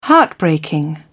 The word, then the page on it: heartbreaking: British English